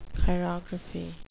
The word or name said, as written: chirography